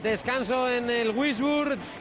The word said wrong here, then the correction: Würzburg: Se escribe W-U, con diéresis esa U, R-Z-Burg. Una amiga mía que sabe mucho alemán siempre me corrige y me dice que es Würzburg. Pero claro, Würzburg.